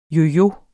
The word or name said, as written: Yoyoer